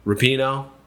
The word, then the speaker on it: Rapinoe: Rapinoe, did I say her name right? – Yes, you did.